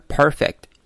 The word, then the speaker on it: perfect: Perfect，我们先注意到第一个音节中的卷舌音哈 perfect。那么后面的 fact，字母 c 所对应的这个爆破音在这里受阻了。发音的时候，由于后面也是接另外一个这个爆破音，我们在发的时候呢，声音不用出来，戛然而止啊。